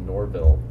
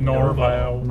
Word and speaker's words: Norville: It's Norville. – Norville. How did I say it? – said Norval. – Norval. I thought it was French.